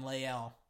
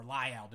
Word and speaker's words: Lael: They have Tyron Smith at the left, then Connor, then Travis, then Zach, and then Lael, or Lyell, depending on how you want to pronounce his name.